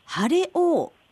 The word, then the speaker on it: 晴れ王: で、岡山県のシャインマスカット、晴れ王、まあ、晴れの王と書いて晴れ王と呼ばれていますよね。